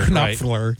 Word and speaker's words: Knopfler: Knopfler. – Right. The K is silent.